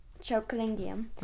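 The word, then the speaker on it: Chokalingam: Oh, I don't even know how to pronounce it, to... – I think you did pretty good. – Really? – Chokalingium. – Chokalingium.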